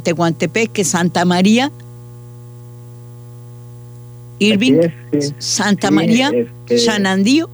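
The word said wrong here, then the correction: Xadani: ¿o cómo se pronuncia? – Shadani. – Muy Santa bien. – María Shadani.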